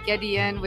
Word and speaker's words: Gedeon: It's Daizy with a Z, so D-A-I-Z-Y. Gedeon with a G-E-D-E-O-N.